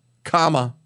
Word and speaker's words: Kamala: By the by, there's a guy has a piece out at the Wall Street Journal about how to pronounce the name Kamala. Kamala. She says it's comma, as in, you know, the little punctuation mark. C-O-M-M-A dash la. Kamala.